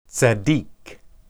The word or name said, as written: tsakiq